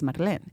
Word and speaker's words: Marlén: Your name is Marlene. And you need an accent on your name. And I was like, what? She was like, yeah, that's how you pronounce it. Phonetically, it needs an accent.